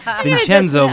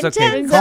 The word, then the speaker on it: Vincenzo: And when we come back, we're going to be talking with Vincello. Did I say it right? – Vincenzo, but it's Vincenzo.